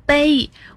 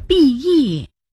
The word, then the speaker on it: 裨: 我还以为那个字读作卑，我常说不无卑意，原来正确的是不无裨益。